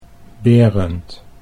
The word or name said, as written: Berend